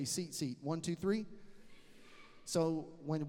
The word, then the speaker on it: tzitzit: It's spelled T-Z-I-T-Z-I-T. It sounds like zit-zit, but it's tzitzit.